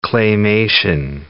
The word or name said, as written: Claymation